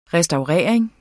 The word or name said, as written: restaurering